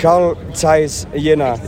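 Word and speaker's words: Carl Zeiss Jena: And the team you support is Karl Gent... How do I pronounce it in English? – Karl Zeiss Jena.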